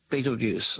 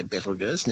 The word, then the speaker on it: Betelgeuse: But the companion star is orbiting Betelgeuse, or I should say Betelgeuse, now we're pronouncing it correctly, and so that particular star is going to be studied a great deal now.